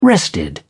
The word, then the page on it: rested: British English